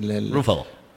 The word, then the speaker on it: Ruffalo: Ruffalo. – Sí, ¿no? Uh-huh. Bueno, porque en realidad es italiano, pero vos lo estabas diciendo la versión gringa como...